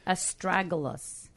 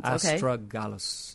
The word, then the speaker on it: Astragalus: Astragalus. – Correct. – And it's spelled just the way it sounds. – Astragalus, yes.